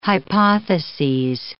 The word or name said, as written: hypotheses